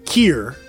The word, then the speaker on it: Kir: And then I fucking listened to some YouTube videos and it's indeed called Kier. – Kier. – Because it's French. Like Kier.